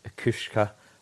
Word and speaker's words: Kucka: Then I think Itubu will be as well, and then it's just going to be if Kushka, is that how you pronounce it? – Nailed it, man.